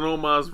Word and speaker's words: Omos: New Day, Kingston and Woods defended against AJ Styles and Omos, if I'm saying that right? – Omos.